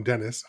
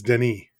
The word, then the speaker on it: Denis: I had been calling him Dennis. I apologize. It's Denis. – Oh, okay, good. – He's French, so it's of course, Denis Villeneuve.